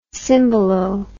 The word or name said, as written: cymbalo